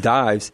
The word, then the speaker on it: Dives: Right, instead of Dives, Lazarus and Dives. And Father said, actually, that's a proper pronunciation from the old English. That's how they used to pronounce it, is Dives.